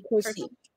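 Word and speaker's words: Kersee: Okay, so my question is, who is Jackie Joyner Kearse? I think that's how you pronounce the last name. – Kearse.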